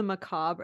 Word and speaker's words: macabre: So then she goes on to talk about how she used to love the macabre. And I was like, yeah, girl, like that was your whole thing. – What is the macabre? Is that how you say it?